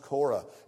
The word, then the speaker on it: Korah: Spelt here C-U-R-E. It's pronounced Korah.